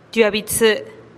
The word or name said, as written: tiuaa bi tsï